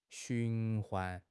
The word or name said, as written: 循环